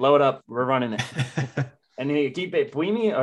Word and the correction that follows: Bielema: or how do you say bulimia? Blamia? – Bielema. – There we go.